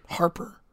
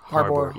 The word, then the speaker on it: Harbour: Harper. – No, it's like David Harper. – No. – It's like no, it's David Harbour. Harbour.